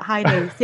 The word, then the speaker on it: Hainanese: Hainanese, yeah.